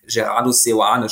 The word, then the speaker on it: Gerardo Seoane: Gerardo Seoane spricht man ihn, äh, glaube ich, aus. Äh, ja, das hat er bestätigt.